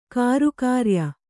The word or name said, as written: ಕಾರುಕಾರ್ಯ